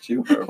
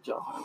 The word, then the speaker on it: Jew harp: It's pronounced Jew harp. – What do you mean? – What are you confused about? – Could have sworn you said jaw harp.